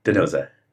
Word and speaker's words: D'Souza: I always get the name wrong. Denise D'Souza? – D'Souza. – D'Souza, yeah.